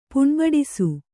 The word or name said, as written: ಪುಣ್ಬಡಿಸು